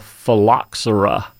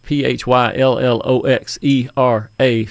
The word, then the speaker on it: phylloxera: It's called phylloxera, and it's spelled with a P H P H Y L L O X E R A phylloxera.